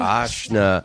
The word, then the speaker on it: Ashna: Ashna.